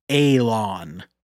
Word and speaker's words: Alan: And the voice returns and says, "My name, he doesn't say this, it's spelled Alan, A-L-A-N, but it is pronounced Alon.